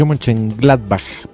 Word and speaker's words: Mönchengladbach: Borussia Mönchengladbach, pronuncias ahí el batch final como una J, Borussia Mönchengladbach.